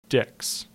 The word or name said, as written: Dix